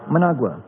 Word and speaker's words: Managua: Managua. How do you pronounce it again? Managua. Thank you.